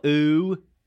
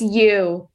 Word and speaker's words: ewe: We watched, I believe it's episode 5 from season 1, and it's called Hello Ooh E-W-E. – I actually didn't know how to pronounce that. Was it? – I think it's ooh. – I mean, what is that? – It's you.